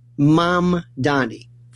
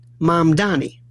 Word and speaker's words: Mamdani: Mamdani. First point is a very simple thing. Three syllables. Mom, like mom and dad. Donnie, like Donny Osmond. Mamdani. Simple. Mamdani.